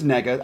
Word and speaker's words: Nega: Nega. – N-word. – Yeah. – And then... – I'm not going to be scared to say it. Her last name is Nega. I'm saying it correctly.